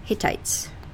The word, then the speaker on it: Hittites: Hittites. – Hittites.